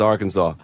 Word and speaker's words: Arkansas: And it is pronounced Arkansas.